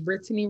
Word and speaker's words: Brittany: I am Brittany Wright, and I'm the youngest. – And she only pronounces that middle syllable when she's recording, because she's Brittany in every other circumstance.